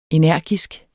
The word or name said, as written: energisk